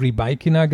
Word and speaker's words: Rybakina: Also sie hat der BBC wohl wirklich gesagt, die hat nachgefragt, die BBC fragt mal nach, hat das eben dieses Rybakina gesagt und so hat sie dann zu Barker auch ausgesprochen.